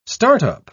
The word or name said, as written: start-up